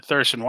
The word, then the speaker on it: Thurston: Thurston. Am I pronouncing that one right? – You are correct, sir. – All right, Thurston, why don't you describe yourself to us now?